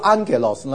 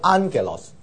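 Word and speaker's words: Angelos (ἄγγελος): 在希腊文 double gamma 呢，它会变成是这个发音，是 NG 的发音，所以 N 是这个发音，所以变得是 Angelos double G 它会变成的发音呢，因为两个 G 很难都是重都是喉音后面，所以很难发，所以就把前面一个变成是鼻音啊，new 啊，所以发音是 angelos 啊，天使或是 messenger，angel or messenger。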